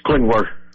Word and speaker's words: Klingler: It's Klingler. – Klingler. There's no extra syllable in there. – There's no ing in there.